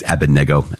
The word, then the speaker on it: Abednego: I always go back and forth with Abednego and Abednego. It's Abednego, I hear, because I believe the accent is on the third syllable.